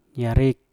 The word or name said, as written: yarep